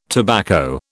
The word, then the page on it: tobacco: British English